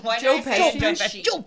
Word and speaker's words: Joe Pesci: Joe Pesci. You have to say it like that.